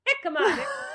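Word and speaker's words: jícama: Who knew that hemica, am I saying that right? Hemica. How do you say that? – Jicama? – Nope. Jicama. I say, loser.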